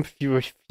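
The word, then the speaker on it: Fjölnir: Fjolnir.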